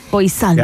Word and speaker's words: Poissant: Es de un escritor gringo que se llama David James Poissant. Se escribe Poissant, probablemente se dice, pero es P-O-I-S-S-A-N-T. – Poissant. – Yeah. Poissant.